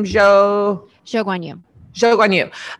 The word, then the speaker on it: Zhou Guanyu: Zhou Guanyu. – I think Zhou Guanyu does not finish.